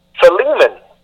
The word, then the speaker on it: Philemon: For example, I used to call this book Philemon, with the emphasis at the beginning with the P-H-I. However, after careful research, the correct pronunciation of the word in English is Philemon, with the emphasis on the L-E.